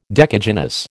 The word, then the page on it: decagynous: American English